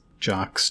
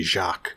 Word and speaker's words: Jock: So where is Jock's journey? I keep wanting to say Jacques, but it's Jock with a hard J.